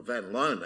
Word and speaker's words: van Loon: And van Loon was, or van Loon actually is the way they pronounce it, born in the Netherlands but wrote in the United States.